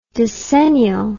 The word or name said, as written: dicennial